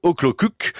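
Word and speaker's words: Okjökull: Scientifiques et chercheurs s'apprêtent à dévoiler une plaque commémorative sur le site de ce glacier, Loggjöfull, prononcé Oklokuk, auquel a été enlevé justement son statut de glacier depuis quelques années.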